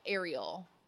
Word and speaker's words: A.Real: In August, on the 21st, a four-member girl group from Jay-Z Factory Entertainment, Ariel, but it's spelled like a real, A-R-E-A-L, Ariel.